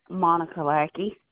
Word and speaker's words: Monica Lackey: Monica Lackey.